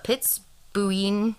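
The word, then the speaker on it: Piz Buin: The Pitsbuin, sorry, that's the pronunciation they gave me, website.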